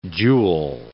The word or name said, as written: joule